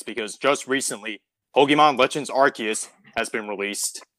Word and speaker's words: Arceus: So, fun fact, it's officially, it's one of the only Pokemon to have an official canon pronunciation because Arce is a term for butt. Like, it's slang for ass in Britain. So the translators flat out said, like, yeah, no, it's Arceus.